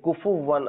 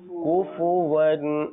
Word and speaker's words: كفوا: كفوا كفوا খেয়াল করুন উচ্চারণে এই এ উচ্চারণ ক্ষেত্রে আমরা একটু ভুল হয় যে কুফুও ওয়ান আহাদ অনেক সময় হয়ে যায় এটা কি হবে কুফুওয়ান আহাদ